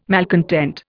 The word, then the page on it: malcontent: Indian